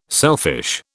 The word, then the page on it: selfish: British English